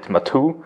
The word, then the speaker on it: Maatoo: The name of the product is Matu. Is that the proper pronunciation? – Yeah. Exactly. So in German, we call it Matu.